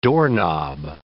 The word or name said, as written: doorknob